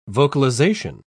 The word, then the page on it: vocalisation: British English